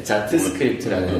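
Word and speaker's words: Jot Script: 그 다음에 이제 제일 어, 관심이 있었던 거는 이제 이제 자스크립트라고 해서 음. 어, 이 발음 조심해. 네, 네, 자트스크립트라는 음.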